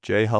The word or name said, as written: Jay Huff